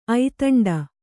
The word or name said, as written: ಐತಂಡ